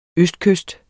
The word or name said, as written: østkyst